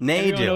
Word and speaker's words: Nadu: Everyone Nadu.